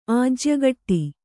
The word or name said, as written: ಆಜ್ಯಗಟ್ಟಿ